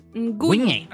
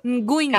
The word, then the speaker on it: Nguyen: Nguyen. – Ó. – É o sobrenome, você não faz o nhe, você faz só o Só o som do, do N fechado. Então, Nguyen.